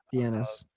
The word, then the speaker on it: Dienes: We do have Bay Village Police Department on record in the papers saying that they wanted to speak with Frank Denis, or Denis, if I'm saying his name right, D-I-E-N-E-S. – Dienis.